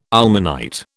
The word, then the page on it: ilmenite: British English